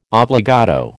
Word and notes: obbligato: American English

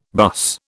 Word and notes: buss: British English

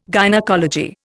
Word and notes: gynaecology: Indian

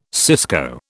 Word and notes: cisco: British English

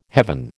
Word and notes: heaven: American English

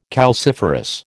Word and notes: calciferous: American English

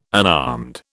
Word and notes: unarmed: British English

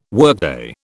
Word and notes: workday: British English

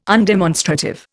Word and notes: undemonstrative: Indian

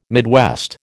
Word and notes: Midwest: American English

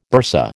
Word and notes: bursa: American English

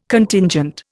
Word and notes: Contingent: Indian

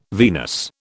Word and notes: venous: British English

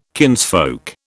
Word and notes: kinsfolk: British English

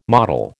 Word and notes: mottle: American English

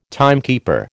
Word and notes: timekeeper: American English

